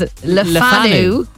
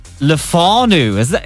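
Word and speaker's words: Le Fanu: It's spelt L-E space F-A-N-U. So we thought that it was Lefanu. Like very French. – Yeah. – Lefanu. – Everyone in Ballyfermot corrected us and said it was Lefanu.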